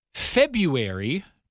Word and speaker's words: February: Lucky, February, don't forget that silent R in the middle, mirror, dial, note, and discover.